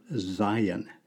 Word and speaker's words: Zayin: Zayin. We'd spell it Z-A-Y-I-N in English.